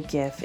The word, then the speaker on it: GIF: The creators of the GIF insist that the proper pronunciation is with a soft j sound, like Jif peanut butter.